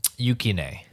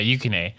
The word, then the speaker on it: Yukine: Yes, when she saves, basically Yato's life, because the third character, Yukine, how do you pronounce his name again? – Yukine. – Yeah, Yukine.